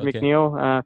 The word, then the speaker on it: McNeil: Jared McNeil. Yeah, as long as you spell it J-A-R-R-Y-D. – It'll come up. – M-C-N-E-I-L.